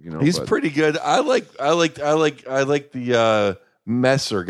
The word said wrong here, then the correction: Mersch: guy better though, or however you pronounce his name. – Oh, Mersch. – Mersch.